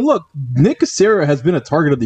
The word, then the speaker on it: Caserio: And that is the Houston Texans hiring Nick Caserio. Am I saying that correctly? – Yes, you are.